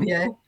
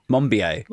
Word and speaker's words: Monbiot: So George Mom, I'm I'm positive I'm not pronouncing his name properly. – George, George Monbiot. – Monbiot.